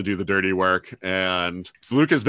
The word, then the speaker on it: Seleucus: I've always pronounced it Seleucus because it's a C. You know, it's a hard C. Apparently it's a hard C, and I didn't realize that. I've never heard it said. I've only ever read the name. And so I always want to say Seleucus, but they're saying Seleucus in the story, which is probably actually accurate.